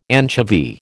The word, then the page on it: anchovy: American English